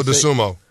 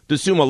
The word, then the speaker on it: Dosunmu: Ayo Dosunmu. – Yeah, Dosunmu.